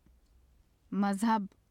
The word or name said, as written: mazhab